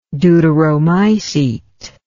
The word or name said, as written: deuteromycete